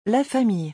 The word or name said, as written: la famille